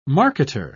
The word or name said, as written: marketer